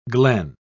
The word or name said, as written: glen